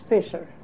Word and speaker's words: Viser: Well, in Holland we spelled it V-I-S-E-R, but it was pronounced Fisher. Because the V is pronounced F in Holland.